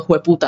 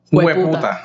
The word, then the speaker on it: jueputa: Sí, porque no decimos hijo de puta, decimos jueputa. – Hijo de puta. – No, jueputa. Jueputa. Jueputa. Jueputa. – Y se escribe con J, jueputa.